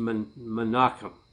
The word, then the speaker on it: Menahem: Menahem. It would probably be pronounced Menachem, like Menachem Begin, who was a prime minister of Israel decades ago, knew Mr. Armstrong.